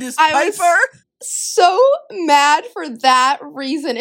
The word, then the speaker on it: Piper: We have gotten on her case so much for pronouncing things wrong, like Pipper, Pipper oh instead of gosh. Piper.